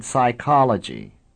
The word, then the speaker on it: psychology: This situation is more or less comparable to combinations like PS in psychology, in which PS is pronounced like S, or like KN in no, which is pronounced like N.